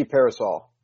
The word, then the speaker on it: Pearsall: Is it Pearsall? Is that how you pronounce his name? – Ricky Pearsall. – Ricky Pearsall. Okay.